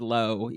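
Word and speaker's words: Loo: It is pronounced low, even though it's L-O-O.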